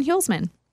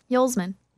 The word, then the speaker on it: Hulsman: Morgan Hulsman. – Oh, I thought it was Huselman. – Hulsman. – Okay. – Like Yule, but Hule.